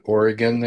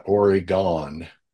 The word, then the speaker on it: Oregon: we actually pronounce it Oregon there, not Oregon.